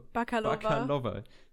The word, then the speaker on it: Bakalova: Wir hatten eine Maria Baklavo Bakalova. Es tut mir leid, dass ich den Namen so beschissen ausspreche die hat die Tochter von Burat gespielt im zweiten burat film Und ich fand sie sogar echt stark.